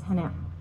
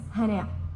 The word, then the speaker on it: Hana: Thank you, Hacham Sam, for pronouncing my name in Hebrew correctly. And in Arabic, it's Hana. My name is Hana.